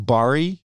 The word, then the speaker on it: Barre: Which led to, I think it's pronounced Barre, B-A-R-R-E.